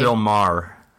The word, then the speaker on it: Bill Maher: There was a little bit of a story in the news the last couple of days with is it Bill Maher? Is that how you pronounce his name? – Bill Maher. Yeah. – Maher.